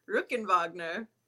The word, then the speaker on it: Rockenwagner: Did I how do you pronounce it? – Rockenwagner. – Rockenwagner. – Well, so I really butchered that. Okay. – No, it's okay. – My apologies. – Americans can say it that way.